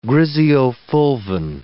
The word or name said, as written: griseofulvin